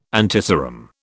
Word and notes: antiserum: British English

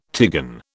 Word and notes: tigon: British English

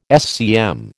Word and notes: SCM: American English

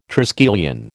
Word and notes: triskelion: American English

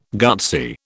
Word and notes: gutsy: British English

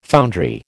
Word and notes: foundry: American English

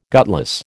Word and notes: gutless: American English

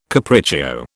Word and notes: capriccio: British English

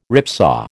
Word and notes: ripsaw: American English

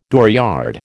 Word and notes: dooryard: American English

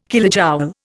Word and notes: kilojoule: Indian

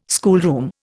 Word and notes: schoolroom: Indian

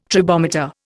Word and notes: tribometer: Indian

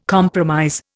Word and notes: compromise: Indian

